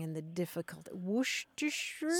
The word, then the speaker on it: Worcestershire: Worcestershire mm-hmm.